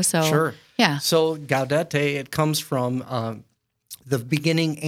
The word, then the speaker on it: Gaudete: So the third Sunday of Advent, I don't know how to properly pronounce that Sunday. – Gaudete.